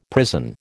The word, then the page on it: prison: American English